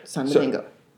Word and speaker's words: Saint-Domingue: Saint-Domingue.